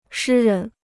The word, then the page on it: 诗人: shī rén